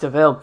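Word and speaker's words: De Vil: It's spelled devil, but it's pronounced DeVille.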